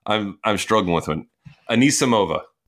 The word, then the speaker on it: Anisimova: Am I saying that right, by the way? Anamasova? – No, I think you're saying it wrong. – Anisimova. – Anisimova. – You're switching the S and the M.